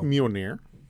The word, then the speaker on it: Mjolnir: It's pronounced Mjolnir.